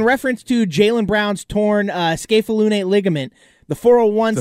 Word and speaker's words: scapholunate: I literally looked up a video on how to pronounce it. – Great. – It's scapholunate.